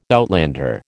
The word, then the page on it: outlander: American English